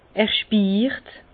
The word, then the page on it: er spiirt: Bas-Rhin; Herrlisheim